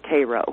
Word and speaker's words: Cairo: Now, Cairo, Illinois, it's spelled Cairo, but around here we call it Cairo, is a very, very small tip of Illinois at the confluence of the Ohio and the Missouri River.